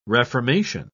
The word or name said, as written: reformation